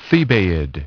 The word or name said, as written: Thebaid